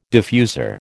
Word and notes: diffuser: American English

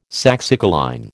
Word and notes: saxicoline: American English

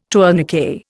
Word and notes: tourniquet: Indian

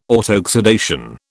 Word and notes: autoxidation: British English

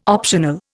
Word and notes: optional: Indian

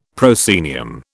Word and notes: proscenium: British English

